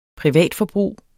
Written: privatforbrug

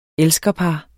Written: elskerpar

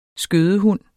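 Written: skødehund

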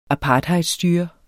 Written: apartheidstyre

